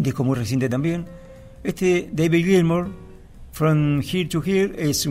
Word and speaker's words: Gilmour: el guitarrista de Pink Floyd, se escribe diferente, se pronuncia más o menos igual. David Gilmour es este guitarrista,